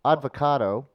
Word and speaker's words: avocado: You know, I was reading that Advocato. – Who? – Advocato. Avocado. – Sound it out. – Who? Avocado. – Avocado. What did I say? – Advocado. – I said odd. – You're throwing in syllable.